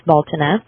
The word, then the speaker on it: Beltane: July 4th is exactly the 66th day following the beginning of the Satan High Holy period called Baltana. It's spelled Beltane, but it's pronounced Baltana.